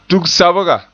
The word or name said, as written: tug-sabga